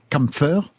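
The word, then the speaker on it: Kämpfer: Le, titre, c'est Kampfer, Hein pas mieux dans la prononciation. Parfait.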